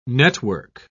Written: network

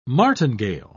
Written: martingale

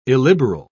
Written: illiberal

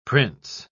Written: prince

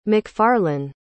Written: mcfarlane

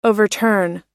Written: overturn

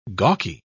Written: gawky

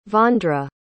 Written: vondra

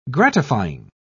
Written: gratifying

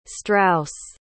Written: strauss

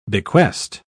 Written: bequest

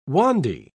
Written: wandy

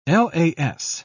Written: las

